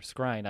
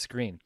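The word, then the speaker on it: Skrine: And if I said the other, Scrine, I apologize. I'm still getting used to that one. It reads as Scrine, but it's pronounced as Screen.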